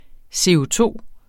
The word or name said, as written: CO₂-udledning